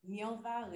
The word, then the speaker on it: Nirva: Nirva Rebecca LaFortune.